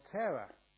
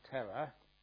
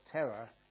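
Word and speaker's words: Thera: And as you know, that you know, after 10 years as a monk or a nun, you're called Tera. Now, that's not pronounced the same as Tera, but Tera. Sometimes people think, you know, why are you called a Tera after you've had 10 reigns? It's Tera.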